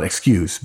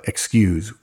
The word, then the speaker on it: excuse: The word to remember is excuse, pronounced with a Z, folks, and not an S. It's not excuse, but excuse.